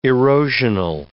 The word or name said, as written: erosional